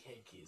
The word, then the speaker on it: Heinicke: Heinicke? – I think Heinicke is correct. – I don't think it's pronounced like Heinicke, the beer.